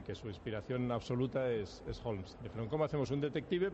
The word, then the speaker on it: Holmes: Si a Holmes le quitan la L, que en inglés ni la pronuncian, pues ya tienes casa y casa, los dos se llaman casa, ¿no? – Sí, ya es curioso.